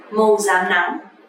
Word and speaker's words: rám nắng: Actually, to pronounce correctly is R, R sound, but lately nobody pronounced like that. You can actually pronounce like Z, Z sound, just like Z sound in English is okay. It's totally fine. So when you say the word màu rám nắng, you don't have to pronounce màu rám nắng, but you can pronounce màu rám nắng.